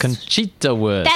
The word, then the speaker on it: Conchita Wurst: Conchita Wurst.